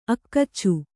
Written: ಅಕಚ

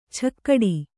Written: ಛಕ್ಕಡಿ

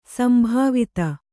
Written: ಸಂಭಾವಿತ

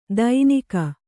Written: ದೈನಿಕ

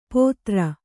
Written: ಪೊದರ್

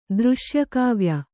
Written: ದೃಶ್ಯಕಾವ್ಯ

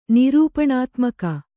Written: ನಿರೂಪಣಾತ್ಮಕ

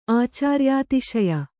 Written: ಆಚಾರ್ಯಾತಿಶಯ

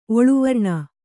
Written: ಒಳಾವರಣ

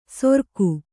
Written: ಸೊರ್ಕು

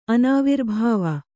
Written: ಅನಾವಿರ್ಭಾವ

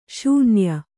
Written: ಶೂನ್ಯ

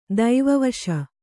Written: ದೈವವಶ

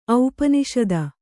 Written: ಔಪನಿಷದ

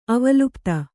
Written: ಅವಲಿಪ್ತ

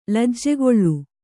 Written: ಲಜ್ಜೆಗೊಳ್ಳು

